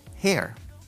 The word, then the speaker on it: hair: Con estas primeras dos me hacía bolas, porque cabello se dice hair, hair, y ella se dice her. Pero yo decía, cuando veía la E, yo pronunciaba hair para, usar el pronombre, el pronombre personal. Cuando, estaba diciendo, cuando decía eso, estaba diciendo cabello. Hair her.